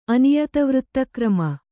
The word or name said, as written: ಅನಿಯತವೃತ್ತಕ್ರಮ